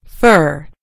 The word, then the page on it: fur: American English